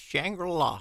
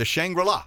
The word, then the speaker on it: Shangri-La: Is it? I'll let you pronounce that. – USS Shangri-La. – Okay, the Shangri-La. I thought that's how you pronounce it, but I didn't want to screw it up.